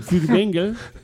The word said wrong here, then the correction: Furtwängler: Si lo pronuncio bien. ¿Furtwängler o Fürbengel? – Furtwängler.